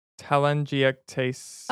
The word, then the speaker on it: telangiectasia: Telangiectasias. – Oh, damn, you're worse than me. Telangiectasias. – Telangiectasia. That's not how it's spelled. – That's how it's pronounced.